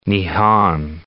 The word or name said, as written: Nihon